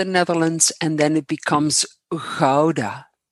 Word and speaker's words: Gouda: Gouda. The G's in all Dutch are kind of silent or glottal? – They're kind of glottal unless okay.